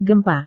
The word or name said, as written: gempa